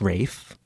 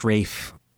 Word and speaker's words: Ralph: English call it, I guess, Rafe. And if I'd known that it could be pronounced Rafe when I was in elementary school, it would have saved me some grief, because Happy Days was popular at that time.